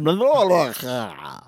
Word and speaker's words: Mallorca: You know, when I said Mallorca, when we were online for the ferry to get there, mm-hmm. and the woman behind me corrected my pronunciation.